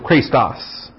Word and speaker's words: Chrestos: This is the adjective, Christos. C-H-R-E-S-T-O-S. Not to be confused with Christos, which is Christ, or anointed one. Christos. C-H-R-E, again, that's the long E, the eta, S-T-O-S, Christos.